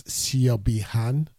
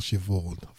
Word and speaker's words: Siobhan: That was Jason Ribello from his album called A Clearer View, and that's Siobhi Han. Actually, it's called Siobhan. That's the way it's spelled. S-I-O-B-H-A-N. Siobhi Han. The Irish, they have got a lot to answer for.